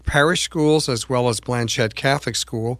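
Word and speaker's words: Blanchet: It's named after the first Catholic Archbishop of the Northwest, Archbishop Francis Norbert Blanchette. And by the way, his family pronounced it with the hard T, even though they were of French heritage.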